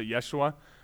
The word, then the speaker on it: Yeshua: Jesus is simply the Greek way of actually saying the name Joshua. Or you could even more accurately pronounce it Yeshua.